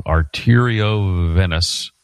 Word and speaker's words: arteriovenous: But the condition that I had is a rare spinal condition called spinal dural arteriovenosis. No, I'm saying it wrong. Arteriovenous fistula.